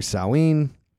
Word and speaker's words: Samhain: about the dead roaming around during Samhain. Proper pronunciation by Conal Cochran, by the way. I think Dr. Loomis drops the ball on the pronunciation in Halloween too, if I'm not mistaken.